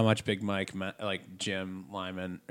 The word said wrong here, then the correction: Lynam: I said, that's what I was saying it wrong. It's Lynam. – It's Lynam. – I probably said Lyman in the past. It's okay. Lynam.